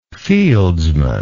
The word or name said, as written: fieldsman